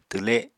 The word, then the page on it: twle: apatani